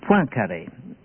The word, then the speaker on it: Poincaré: A classic example of insight is the case of the French mathematician Poincaré. I'll spell that. P-O-I-N-C-A-R-E.